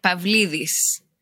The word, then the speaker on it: Pavlidis: I mean, if you were Greek, you would say Pavlidis. But here Pavlidis. You can say it. You know, whichever way you like.